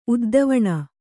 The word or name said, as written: ಉದ್ದವಣ